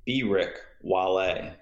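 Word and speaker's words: Beerick-Walle: B Rick Wale.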